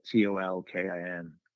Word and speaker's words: Tolkin: And then also going back to like this Sid Caesar show of shows, Sid Caesar wasn't the head writer, Mel Tolkien was. And so I think that's how you say it, Tolkin.